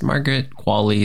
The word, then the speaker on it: Margaret Qualley: That's Margaret Qualley is correct, I think.